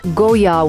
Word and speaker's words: Goyard: Goyard, it's written Goyard, but it's actually pronounced Goyard without the D at the end.